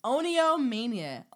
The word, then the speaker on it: Oniomania: Oni- I said Onio. Sorry. It's Oniomania. Oniomania. Oniomania. Let's spell that. O N I O M A N I A.